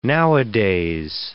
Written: nowadays

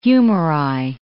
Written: humeri

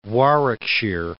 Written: Warwickshire

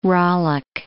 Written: rowlock

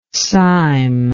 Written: cyme

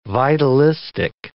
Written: vitalistic

